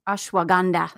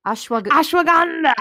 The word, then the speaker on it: ashwagandha: It's full of plant protein, essential amino acids, vitamins, minerals, mint, beet, matcha green tea, wheatgrass, ashwagandha. Did I say that one right? – Ashwagandha. – How do I say it? – Ashwagandha.